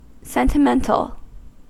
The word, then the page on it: sentimental: American English